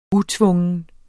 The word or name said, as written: utvungen